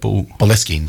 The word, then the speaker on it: Boleskine: Boleskine.